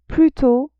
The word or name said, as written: plus tôt